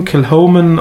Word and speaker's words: Kilchoman: Kilchoman geschrieben, Kilhoman ausgesprochen.